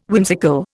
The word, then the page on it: whimsical: Indian